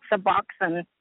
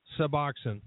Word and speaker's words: Suboxone: I just wanted to let you know, and it's pronounced Suboxone. – Suboxone.